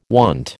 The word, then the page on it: wont: American English